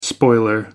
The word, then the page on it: spoiler: American English